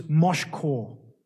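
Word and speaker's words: moshchah: It's spelt M-I-S-H-C-H-A-H. M-I-S-H-C-H-A-H. And there is another Hebrew word for this, which is moshkor. Moshkor. It's spelled M-O-S-H-C-H-A-H. C-H-A-H, M-O-S-H-C-H-A-H.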